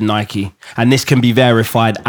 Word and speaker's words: Nike: No, it's Nike. Because then saying it's the American pronunciations, Americans speak English.